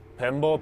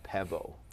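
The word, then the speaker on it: pembo (πέμπω): So, yeah, so basically, the pronunciation on this one with the modern pronunciation, the MP actually can either make a MB sound. I said pempo, but really it's like you said, pembo is better. But basically, sometimes they just do it as just a B too, just pebo.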